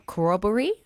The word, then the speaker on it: corroboree: 800 critically endangered corroboree, am I saying that correctly? – Yep.